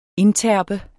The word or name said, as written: indterpe